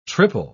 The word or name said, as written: triple